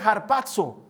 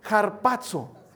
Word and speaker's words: harpazo: Por eso es que se llama arrebatamiento, es una palabra griega que se pronuncia harpazo. A ver, di conmigo, harpazo. Otra vez, harpazo.